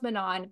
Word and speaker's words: Manon: There has been so much debate over it, but I feel like it's Manon, and I know it's pronounced Manon, but to me it's Manon.